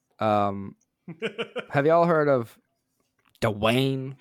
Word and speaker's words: Dwayne: The G was a little hard there. – Dwayne Dwayne Johnson. – The G is silent. – Take what you need from this. So, Dwayne The Rock Johnson.